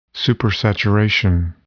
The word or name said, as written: supersaturation